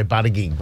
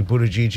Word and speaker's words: Buttigieg: I hope I have pronounced your name properly, Buttigieg. – One second. That's the Maltese pronunciation. Yes. A lot of people say Buttigieg, but it's actually Buttigieg is the correct pronunciation, so well done.